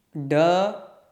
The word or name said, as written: ḍ